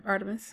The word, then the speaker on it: Artemis: One is called Hotel Artemis. Am I saying that right? – Artemis.